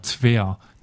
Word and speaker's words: Tver: Tver, T-V-E-R geschrieben.